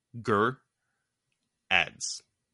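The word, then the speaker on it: Geradz: It's G E R. So so this is the pronunciation. G E R and then A D Z. Ger ads. Ger ads. – Man, then people even in cons are butchering it. Like I understand like Gerard... and he says he says good gerads, bad gerads, gerards. Just stop inserting the extra R.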